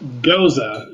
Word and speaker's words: gose: I, after the episode and in editing, realized like I totally forgot about Goes. Can you give me an official, how do you pronounce the G-O-S-E beer? – Goza is how I've heard Goza. It most pronounced in professional circles.